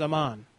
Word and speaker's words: Aman: This is Aman. – How do you spell that? – A-M-A-N.